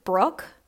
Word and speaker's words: Brooke: So mostly I'm active on Instagram at Courtney Brooke LSW, and Brooke is spelled with an E at the end.